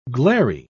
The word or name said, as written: glairy